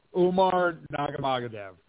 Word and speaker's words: Umar Nurmagomedov: Magurgana Dunabada. How do you say that? You're Magadana Dina. – Oh, I believe he's talking about Umar Nurmagomedov. – Umar Nurmagomedov. Yeah, Umar.